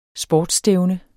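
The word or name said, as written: sportsstævne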